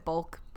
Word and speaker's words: balk: Okay, so it B-A-L-K. Are you saying B-U-L-K? – No, I'm saying B-A-L. I mean, I pronounce it bulk. Like, it's B-A-L-K. – This is, like, a milk situation. Mulk. Malk, Palo. B-A-L-K.